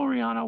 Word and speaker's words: Raihannah: and it's spelled RAIHANNAH. – Okay. – And it's KRYSTAL. Crystal Rihanna Waters is what my Facebook is.